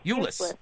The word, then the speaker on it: Euless: Euless. – How do you spell that? – It's like shoeless, but without the S-H. – It's like E-U-L-E-S-S.